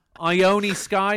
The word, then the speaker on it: Ione Sky: Ione Sky, is that how you say it? – That's how you say it, yes.